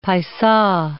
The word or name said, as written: paisa